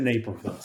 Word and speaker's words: Naperville: And in the notes, it says rhymes with Paperville. I'm thinking Caperville, Vaporville, all sorts of other ones you could come up with. But it's in Naperville.